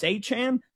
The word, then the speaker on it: Achane: Oh, it's Achane.